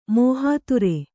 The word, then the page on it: ಮೋಹಾತುರೆ: mōhāture